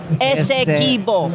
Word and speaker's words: Esequibo: Well, it's kind of like that referendum they held on annexing the Esquibo. – Oh, my God. Stop mispronouncing it, dude. – All right, dude. – Say it correctly. – Esquibo. – Esquibo. – Not Esquibo. Esequibo.